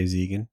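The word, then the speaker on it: Zegen: So we have Joel Maisel, who's played by Michael, I believe you pronounce his last name Ziegen. – I would say Ziegen.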